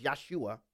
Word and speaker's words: Yeshua: I'll just say this, most of you know this already, but if in case you don't know, Jesus, who the way you pronounce his name in Aramaic is Yeshua, his last name wasn't Christ.